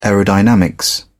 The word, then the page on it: aerodynamics: British English